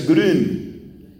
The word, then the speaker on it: Grün: Su apellido es Grun, pero se pronuncia Grun, G-R-U-N, la U lleva diéresis.